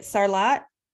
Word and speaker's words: Sarlat: So, how do you how do you say it? Sarlat. Yep, Sarlat Rugby.